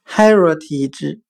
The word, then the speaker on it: heritage: 我们可以用谐音法来记忆这个单词 heritage，它的发音很像汉语的孩若替之。